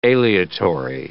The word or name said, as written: aleatory